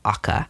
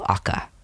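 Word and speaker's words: Aaqa: One such realm is the fabled Akka. I think it sounds pronounced A-A-Q-A, a shining domain of silver spires and verdant gardens atop a fertile earth moat.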